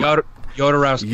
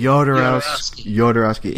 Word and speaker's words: Jodorowsky: Jodorowsky. – Jodorowsky.